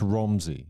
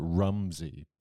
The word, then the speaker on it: Romsey: It's Romsey. – Romsey. – But if you live there, you call it Rumsey, but it's spelled with an O.